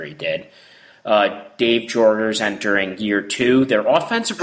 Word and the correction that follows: Jaeger: Did I say his name really wrong? – Jaeger. – Jaeger, like the drink.